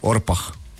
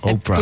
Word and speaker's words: Orpah: Kui muidu kirjutada Oprah on ju, siis tegelikult oli Orpah. – Orpah. – Ma ei tea, kuidas sa hääldada Orpah või Oprah.